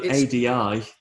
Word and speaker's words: Adi: Although ADI, the name, is pronounced a D.